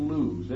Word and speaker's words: Loose: If ever you're round Maidstone in Kent, you run out to a place called Lewes, L-O-O-S-E, Lewes it's spelled.